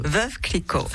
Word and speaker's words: Veuve Clicquot: Veuve Clicquot.